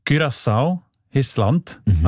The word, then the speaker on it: Curaçao: Habe ich gelernt, spricht man eben mit «ü» aus, weil Holländisch dort auch eine wichtige Sprache ist. Und im Holländischen ist das «ü» offenbar häufig als «ü», Curaçao.